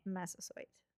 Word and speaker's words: Massasoit: I think you pronounced that absolutely brilliantly. – Massasoit. I tried to write it out phonetically, but then you do, and then you look back and you're like, that's the same as it looks.